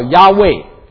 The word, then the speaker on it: Yahweh: YHWH 发音叫 Yahweh，我们中文翻成耶和华。